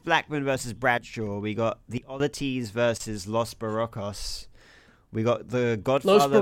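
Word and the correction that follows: Los Boricuas: Los Barriquas. – That one. Sorry. – Yeah. – You know, I butchered that.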